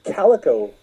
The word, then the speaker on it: Calico: It has Alice Cooper's daughter in it, Calico Cooper. I think that's how it's pronounced, like the cat.